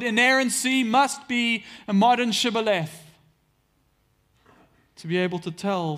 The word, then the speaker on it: shibboleth: Now, Ephraim had a different dialect to their brothers across the Jordan, and they couldn't pronounce the sh sound. And so, when the Gileadites took the Jordan, they made any who would pass through and, and make that crossing, they made them say the word shibboleth. And if they couldn't pronounce it and say, instead said Sibboleth, they killed them on the spot.